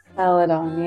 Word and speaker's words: Celadon: It's called, what, Celadon? – Celadon, yeah.